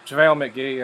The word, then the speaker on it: JaVale McGee: If they're amnestying Blatch, who has, you know, character flaws, they got rid of McVale. How do you say his name? – JaVale McGee, yeah.